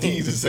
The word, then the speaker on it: Desus: I can't never pronounce their names. – It's Jesus and Mero, nigga.